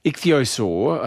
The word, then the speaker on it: ichthyosaur: Scientists in Britain have uncovered the fossilised remains of a giant 180 million year old ichthyosaur. I think that's the correct pronunciation. – That's it.